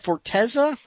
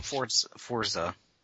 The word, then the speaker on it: Forza: Forteza or? – Forza.